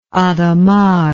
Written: hadamard

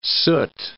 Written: soot

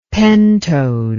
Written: pentode

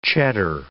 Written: cheddar

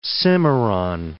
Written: Cimarron

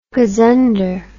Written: gozunder

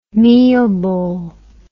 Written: meelbol

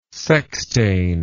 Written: sextain

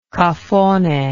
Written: cafone

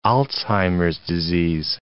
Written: Alzheimer's disease

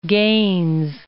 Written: Gaines

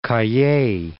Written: Cayey

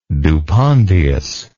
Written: dupondius

